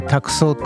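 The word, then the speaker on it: 託奏: えー、これ「ファンティスト」というネーミングメインタイトルもそうですし、えー、その後の「託送カフェ」と、えー、これ、え託されて奏でるって書いて「託送」って読むんですけれども、まあ、ファンティストにしても託送にしてもまあね初めて聞く方なんじゃそりゃっていう話だと思うんですね。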